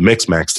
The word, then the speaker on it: Mixmax: So that, along with sending out proposals with Mixmax. I never can pronounce that. – I think you got it right.